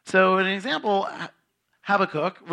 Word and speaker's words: Habakkuk: So to kind of start off, it is pronounced Habakkuk for me. And sometimes I will say Habakkuk because, you know, I feel like it should be an O-O there sound there. And it means embrace.